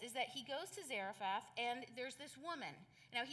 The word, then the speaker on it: Zarephath: So he went to Zarephath. Zarephath, pronounced it wrong the first time.